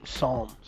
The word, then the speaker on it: Psalm: Psalm. – Psalm. – Psalm. Psalm. Like the book in the Bible. It's a silent P.